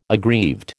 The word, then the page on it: aggrieved: American English